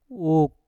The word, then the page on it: uk: mpur